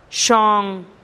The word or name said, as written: xon